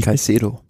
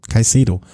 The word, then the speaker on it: Caicedo: Also, Almeida äh, im rosa Trikot vor, ich kann es immer noch nicht aussprechen, Jonathan Clever Chaiko, wie, Caicedo. Caicedo. Wie kann ich mir das merken? Cais, wie ein S, Caicedo. Äh, v.